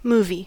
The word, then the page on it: movie: /ˈmuː.vi/